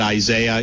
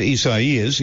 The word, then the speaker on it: Isaias: So, I'm calling it Isaiah. You guys can call it what you want. I know that's not how you pronounce this. It's something else.